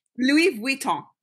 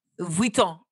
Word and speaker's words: Louis Vuitton: So, okay, so wait, wait, you're pronouncing it properly and everybody else is not. Say it one more time. Which one? – Louis Virgil? – Louis Vuitton. Vuitton.